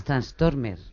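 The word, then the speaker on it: Tranströmer: es facilísimo. Thomas Transtormer. Bueno, como Transformer.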